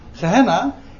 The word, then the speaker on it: Gehenna: Gehenna is gewoon, komt van het Hebreeuwse Gaihinom. Alleen het is de Griekse uitspraak, zeg maar.